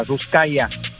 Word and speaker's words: Ruskaja: Ruskaja, se escribe Ruskaja, Ruskaya.